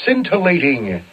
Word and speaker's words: scintillating: This week's word is scintillating. Scintillating, S-C-I-N-T-I-L-L-A-T-I-N-G, scintillating. Yes, the C is silent, or else it would be scintillating, and it ain't. It's not scintillating, it's scintillating.